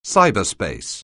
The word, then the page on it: cyberspace: anglais